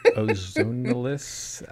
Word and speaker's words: ozonolysis: Ozonolysis. What's the correct way to say this? – Ozonolysis.